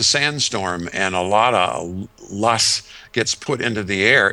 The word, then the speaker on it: loess: There is a kind of soil dust in Judea that's called loess. or probably a better pronunciation is LOESS, L-O-E-S-S.